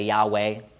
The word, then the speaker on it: Yahweh: In English, we say YHWH. We try to pronounce it. We say Yahweh. Maybe. That's about as close as we can get in our English language.